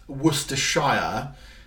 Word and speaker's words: Worcestershire: Now, it's not Worcestershire. It's like Clapham in London is not Clams.